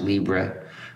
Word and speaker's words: libre: And in English, we might pronounce it libre uh, as a way of showing what we mean when we say free.